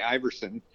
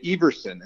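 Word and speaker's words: Ivarsson: In America, we say Iverson, and like the basketball player, Allen Iverson. – Yeah. – In Denmark, they say Everson, and that's the correct pronunciation.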